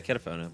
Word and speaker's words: Ketafono: Yeah, Ketafono, but that's all right.